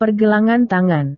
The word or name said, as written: pergelangan tangan